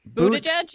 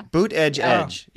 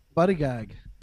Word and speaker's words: Buttigieg: Buttigieg? Yeah. – Boot edge oh. edge. Yes. – Buttigieg. – Yeah. What okay. were you going to go with that one? – Buddy gag.